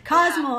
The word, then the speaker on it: Cozumel: You went to Cozumel. Is that how you say it? – Cozumel.